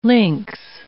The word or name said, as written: links